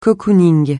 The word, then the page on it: cocooning: francais